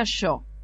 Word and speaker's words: Shaw: El apellido es SHAW y se pronuncia Yo.